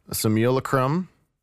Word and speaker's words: Simulacrum: Okay, so on this episode of Mixdown, I have Chrism with me from the band Simulacrum. Am I still saying that right? – Yeah, it's sounding very good.